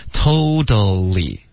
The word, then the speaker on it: totally: Don't bother telling me yours, cause I totally remember it. – 啊就差了一个点,totally, 三个音节刚刚说的 totally, 就变成两个音节了